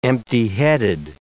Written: empty-headed

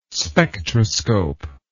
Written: spectroscope

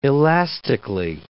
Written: elastically

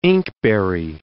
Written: inkberry

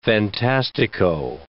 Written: fantastico

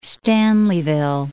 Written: Stanleyville

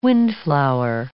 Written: windflower

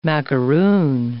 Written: macaroon

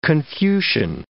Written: Confucian

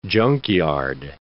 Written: junkyard

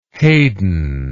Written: Hayden

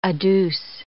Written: adduce

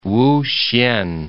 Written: Wuhsien

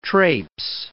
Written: traipse

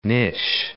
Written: Nish

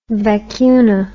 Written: vacuna

